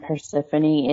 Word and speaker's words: Persephone: And Persephone is the correct pronunciation.